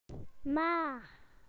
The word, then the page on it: mum: British English